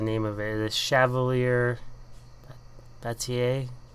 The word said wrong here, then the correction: Chevalier Bataille: Sorry, what? – Chevalier. – Chevalier Bataille. Yeah. Sorry.